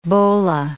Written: bola

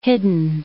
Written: hidden

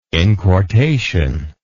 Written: inquartation

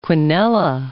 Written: quinella

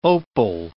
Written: opal

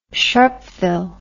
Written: sharpeville

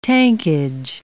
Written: tankage

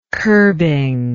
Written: curbing

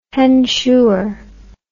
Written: hensure

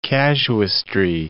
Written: casuistry

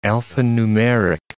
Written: alphanumeric

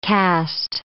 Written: caste

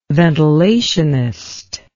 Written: ventilationist

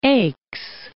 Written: Aix

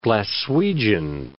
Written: Glaswegian

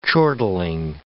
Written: chortling

